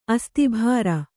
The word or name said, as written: ಅಸ್ತಿಭಾರ